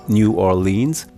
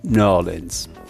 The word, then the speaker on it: New Orleans: Vielleicht ist es ja so, dass je weiter man von der Stadt entfernt ist, desto eher nennt man sie New Orleans. Und je näher man dran ist oder drin ist, desto mehr spricht man von New Orleans.